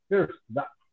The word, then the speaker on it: First Data: A pronúncia está correta? First Data. Legal, né? First Data. Tá, tá sim, tá sim.